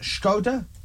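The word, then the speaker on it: Škoda: I think you'll find that's what I said. – No. Yeah. – You said Skoda, it's Skoda.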